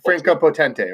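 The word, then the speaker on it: Franka Potente: Franco Potente, right? – Sorry. Yes, you said it right.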